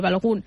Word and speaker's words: Balogun: El tercer, ara l'esmentava el Ferran Correas, suposo que es pronuncia així, el nord-americà Balogun. – Sí, jo li dic així, Balogun.